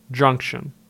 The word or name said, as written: Junction